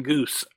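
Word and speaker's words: Guice: Jackson Goose, that's just how you pronounce it, went by the name of Butch.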